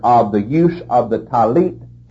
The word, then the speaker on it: talit: Now that prayer shawl is called a talit. You spell that T-A-L-I-T, talit. And the fringes on the border of that garment are called tzitzit, T-Z-I-T-Z-I-T, tzitzit.